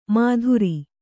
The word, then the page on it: ಮಾಧುರಿ: mādhuri